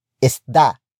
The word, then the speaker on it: isda: It is the focus marker that introduces the main noun of the sentence, which in this case is isda. Isda. Isda. Isda is spelled as I S D A.